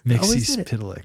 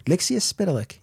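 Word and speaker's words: Mxyzptlk: Mixispitalik. – Mixispitalik. Yeah. – Mixispitalik.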